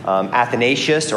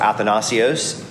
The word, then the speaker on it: Athanasius: Arius was ultimately defeated by another bishop, Athanasius or Athanasios, same person, different pronunciation,